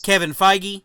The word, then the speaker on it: Feige: Kevin Feige.